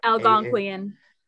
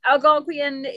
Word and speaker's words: Algonquian: It's supposed to be based off, I have no idea how to say this, Algonquian.